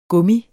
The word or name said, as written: gummi